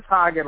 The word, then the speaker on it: Hagen: Sure, it's Chris Hagen, like the ice cream. Some people always ask if I own Hagen Dazs.